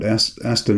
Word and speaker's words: Aster: The main character is someone called Aster, A-S-T-E-R. Would you pronounce it Aster? Aster? – Yeah, that's what I like.